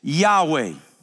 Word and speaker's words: Yahweh: One of them is the pronunciation of the Hebrew letters that sounds like this, Yahweh.